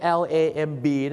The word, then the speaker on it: lamb: มันสะกดเป็น L A M B นี่นะครับผม L A M B เนี่ยมันออกเสียงว่า lamb รู้จักพี่คนนี้ไหมครับพี่อ่างครับ